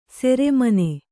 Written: ಶ್ರಮಣ

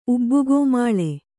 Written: ಉಬ್ಬುಗೋಮಾಳೆ

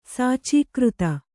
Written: ಸಾಚೀಕೃತ

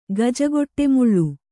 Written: ಗಜಗೊಟ್ಟೆಮುಳ್ಳು

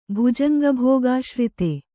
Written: ಭುಜಂಗಭೋಗಾಶ್ರಿತೆ

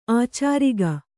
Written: ಆಚಾರಿಗ

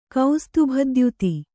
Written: ಕೌಸ್ತುಭದ್ಯುತಿ

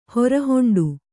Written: ಹೊರಹೊಂಡು

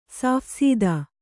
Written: ಸಾಫ್‍ಸೀದಾ

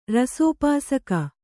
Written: ರಸೋಪಾಸಕ

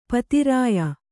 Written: ಪಿತ್ರ್ಯ

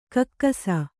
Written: ಕೇಕಿಸು

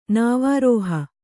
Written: ನಾವಾರೋಹ

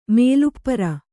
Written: ಮೇಲುಪ್ಪರ

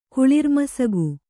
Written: ಕುಳಿರ್ಮಸಗು

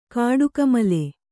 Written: ಕೊಡಗುಮಲೆ

